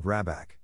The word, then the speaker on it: Rabak: The manor house, or Hergard, in question is to be called Rabak, pronounced something like Roebuck, though that is not its name.